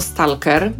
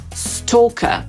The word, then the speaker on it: stalker: To angielskie słowo zapisujemy jako stalker i przyjęło się już w polszczyźnie, że tak właśnie je wymawiamy, jak się pisze.